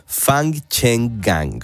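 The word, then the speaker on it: Fangchenggang: Fang Cheng Gang.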